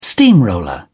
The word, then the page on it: steamroller: British English